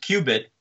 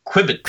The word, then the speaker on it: qubit: So David Merman thinks that it should be, if you're going to pronounce it qubit, and it should be Q-bit. – Okay. How does he want to pronounce it currently, the way that it's spelled? – He says it should be like Quibit. – No, that's wrong.